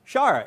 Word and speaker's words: shire: So we had Anyone want to guess how that's pronounced? Shire, exactly.